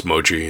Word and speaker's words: mochi: Moki. I don't know how you pronounce that. – Wonderful. It's mochi. Yeah.